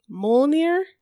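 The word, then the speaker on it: Mjölnir: I actually had to look this up because I knew the name, but I didn't know how to pronounce it. So it's Mjolnir.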